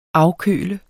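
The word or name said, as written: AFKØLET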